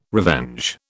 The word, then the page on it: revenge: British English